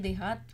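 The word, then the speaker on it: DeHaat: इसका हम प्रोनाउंस कर सकते हैं, देहात भी बोल सकते हैं और डी हार्ट भी कह सकते हैं।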